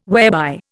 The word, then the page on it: whereby: Indian